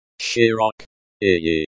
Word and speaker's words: широкий: Б-Е-Р-У-Л-В-Е-Р-У-С-А-Ж-Е-Н-Д-Е-Р-Е-В-Е-Р-Я-М-И. Широкий. Prospect. Bile Vysajan Beriviami. Breakdown. Shiroki. Shirok. Iyi. This is the adjective broad in Russian. It's pronounced as Shiroki.